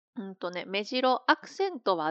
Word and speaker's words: 目白: 目白、これはアクセント 1 で、この鳥は目白、アクセント 0 です。